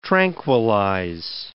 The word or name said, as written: tranquillize